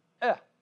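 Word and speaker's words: eh (εἰ): It's pronounced eh, almost like eh, you know, like ick, you almost think of. But it's like eh, and it's the word if.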